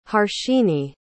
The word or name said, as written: harshini